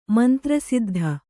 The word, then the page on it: ಮಂತ್ರಸಿದ್ದಿ: mantra siddhi